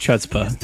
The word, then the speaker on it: chutzpah: It's pronounced chutzpah.